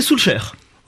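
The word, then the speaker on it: Solskjær: Solskjaer. Voilà, faut prononcer. – On peut dire Solskjaer aussi, non? – Faut le prononcer Solskjaer. – Ouais, dites donc. – À la norvégienne.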